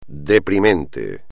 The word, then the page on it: deprimente: espagnol